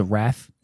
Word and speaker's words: Wraith: You ever see that movie, The Wrath? – Yes. – Or The Wraith, depending on how you want to pronounce it? – Yeah.